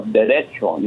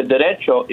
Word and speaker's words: derecho: but I wanted to correct you on your pronunciation of derecho. You say derecho, not that you're, you know, you're saying derecho or whatever. Derecho, C-H, derecho.